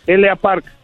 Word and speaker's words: L.A. Park: L.A. Como la L es como la y la A es auténtica y Park es como la K, la K de kilo al último eh, se pronuncia como la Parca.